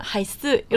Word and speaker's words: 还是: 근데 생각보다 이 사람 대만 사람이어서 우리는 원래 할스 이라고 발음하는데 이 사람은 하이스 이런단 말이에요.